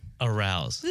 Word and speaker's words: Arauz: Arouse. – It's Arouse. – But it's not spelled like no, no, it's Arouse. – No, it's A-R-A-U-Z as opposed to A-R-O-U-S-E.